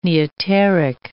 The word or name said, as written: neoteric